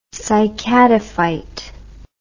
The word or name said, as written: cycadophyte